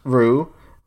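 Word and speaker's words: roux: A Rue, pronounced Rue, is browned in a mixture of white wheat flour and a cooking fat.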